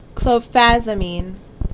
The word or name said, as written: Clofazimine